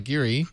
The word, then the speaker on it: Geary: Gary, although it's spelled like Geary in San Francisco.